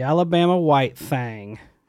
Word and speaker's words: Alabama White Thang: And I am not mispronouncing this. The Alabama White Thang. THANG.